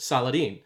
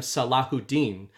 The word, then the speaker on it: Salahuddin: And Saladin, throughout the movie, his name is pronounced by most characters Saladin. It's the quick way to pronounce the name. He changes the pronunciation, which I had seen in one of my deep dives on this film. He uses the original pronunciation. He says, I am not those men. I am Salahuddin, which translates literally to righteousness of faith.